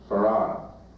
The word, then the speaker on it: Fard: And I believe that the teacher of my father, who you find in the Encyclopedia Britannica under the name Farad, F-A-R-D, pronounced Farad.